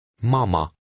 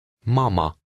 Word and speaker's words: mama: Try to read number two, stressing the first part of the word. – Mama. – Did you hear the stress in that word? In Hungarian, the stress is always on the first syllable of a word. The final A is unstressed and has an ah sound.